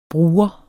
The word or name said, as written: bruger